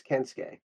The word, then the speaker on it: Kensuke: Kintsugi Sasaki? – All right. So first off, it's Kensuke.